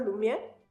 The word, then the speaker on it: Lumière: no, sé cómo se pronuncia en francés, aquí el gordito que eh, ha estudiado francés me puede decir, los hermanos Lumière, eh, hace... – Sí, así se pronuncia.